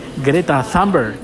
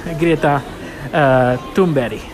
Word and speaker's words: Greta Thunberg: Pues no se llama Greta Thunberg. Bueno, si fuera inglesa o americana, se pronunciaría así. Pero se ve que esta chica es sueca y su nombre se pronuncia Greta Thunberg. no Greta, Greta Thunberg, sino Thunberg.